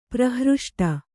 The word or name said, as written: ಪ್ರಹೃಷ್ಟ